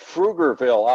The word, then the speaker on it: Pflugerville: Like Pflugerville outside of Austin, Texas. It's spelled with a P, but you don't pronounce the P. You pronounce the F before the P.